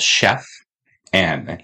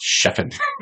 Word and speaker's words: Chef N: but it's just called Chef N. – Yeah, the letter N. – The letter N with an apostrophe after, and it stands for Nathan. – Nathan, who is the owner and chef of the restaurant. So it's Chef N. – Right. – Not the way I pronounce it, Chefin.